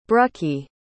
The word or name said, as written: brucie